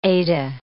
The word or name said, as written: Ede